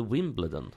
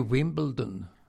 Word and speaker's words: Wimbledon: Eller Wimbledon? Eller? – Alltså, Björn Hellberg säger Wimbledon sen, och det är helt fel. Wimbledon är helt fel. Det heter ju Wimbledon.